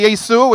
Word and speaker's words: Yesu: If you say Yesu, which is how they say it, they'd say, that's a funny name for grandma, because Ye means grandma.